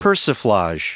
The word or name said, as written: persiflage